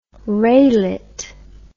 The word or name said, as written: raylet